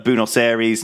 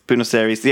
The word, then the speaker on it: Buenos Aires: And so he went off to the South Pole, stopped at Buenos Aires in South Georgia. And it was a long trip. And anyway, they went the whole way around. Buenos Aires, and off to the South Pole. – Buenos Aires? – Fucking Buenos Aires.